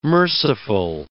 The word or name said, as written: merciful